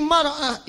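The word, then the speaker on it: mar'ah (مرأة): Ini mar'ah, ini mir'ah. Maknanya bisa beda. Silakan lihat. Ini mar'atun, ini mir'atun. Yang ini perempuan. Yang ini cermin.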